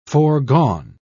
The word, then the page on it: foregone: British English